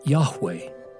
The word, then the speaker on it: Yahweh: But the Hebrew would be pronounced something like Yahweh, and is built on the word for I am.